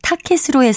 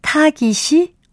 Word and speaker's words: 타깃: 여기서 나온 타켓도 첫째 음절에 있는 거센 소리의 영향으로 둘째 음절도 거세게 발음하는 것 같습니다. 그러나 이 경우에는 타켓이 아니라 타깃이 올바른 표기이자 발음입니다.